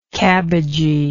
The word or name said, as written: cabbagy